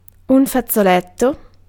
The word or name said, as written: handkerchief